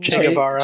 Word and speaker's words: Che Guevara: In che Guevara.